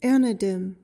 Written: anadem

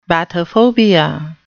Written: bathophobia

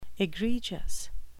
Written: egregious

